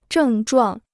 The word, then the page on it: 症状: zhèng zhuàng